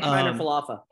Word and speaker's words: Kiner-Falefa: Isaiah Kiner-Falefa.